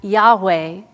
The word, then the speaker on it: Yahweh: For Christians, we pronounce this word as Yahweh,